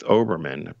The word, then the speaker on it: Olbermann: interesting it's interesting how she got to msnbc keith olbermann am i saying it right mm-hmm.